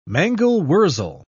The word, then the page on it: mangel-wurzel: British English